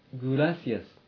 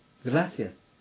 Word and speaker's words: gracias: Gracias. De nada. は、ついになっています。必ず使用する言葉なので、今覚えてください。 Gracias. De nada. Gracias の、ら、は、シーン、シーン、母音です。シーン、へ、と、 r の間には、母音はありませんので、 gracias ではなく、 gracias と発音します。¿Qué tal?